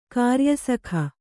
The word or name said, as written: ಕಾರ್ಯಸಖ